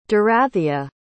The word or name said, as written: Dorathea